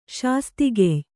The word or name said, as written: ಶಾಸ್ತಿಗೆಯ್